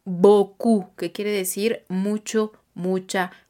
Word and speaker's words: beaucoup: Se escribe J-E espacio T apóstrofo A-I-M-E A-I-M-E y el adverbio boku B-E-A-U-C-O-U-P boku. Entonces, no exageremos el sonido de la O-U-U haciéndolo como una U,